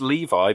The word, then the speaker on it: Levi: It's, it's it's it's Levi because I'm looking it up oh. on Wikipedia, and Wikipedia says it's Levi in the pronunciation thing, but more specifically, it's because it's actually his middle name. His name's Zachary Levi, and Levi as a first name is definitely Levi.